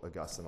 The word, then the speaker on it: Augustine: So, but with a lot of the work of St. Augustine, or Augustine, depending on how you want to say it, if you say it right, it's Augustine, actually.